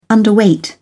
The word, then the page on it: underweight: British English